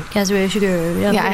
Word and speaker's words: Kazuo Ishiguro: Kazuo Ishiguro.